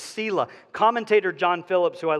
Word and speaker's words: Selah: This word at the end of verse 6 is pronounced Selah. I know because I looked it up. I've heard it pronounced Selah, I've heard it pronounced Selah, and I've heard it Salah. And all of you probably have heard it different ways, but the one that has the most backing is the pronunciation Selah.